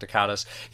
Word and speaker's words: Dacascos: Well, I always say his name wrong. Mark Dacascos.